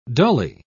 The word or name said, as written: dully